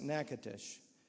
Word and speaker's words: Natchitoches: It's spelled Natchitoches, but it's pronounced Natchitoches.